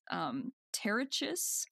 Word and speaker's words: Pterichis: So there's an orchid called I hate this when there's a silent P. So it starts with a P, but I think it's Pterichis.